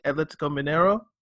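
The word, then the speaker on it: Atlético Mineiro: There was a story that ran that Chapa Coense and I think Atletico Mineiro, if I'm pronouncing that right.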